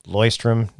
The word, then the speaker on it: Leuchtturm: He goes, Leuchtturm, 1917. Leuchtturm, it's Leuchtturm.